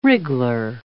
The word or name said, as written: wriggler